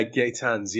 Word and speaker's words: Gaetjens: With G-A-E-T-J-E-N-S. Gaetan? – Yeah, Gaetans.